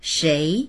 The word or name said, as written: shei2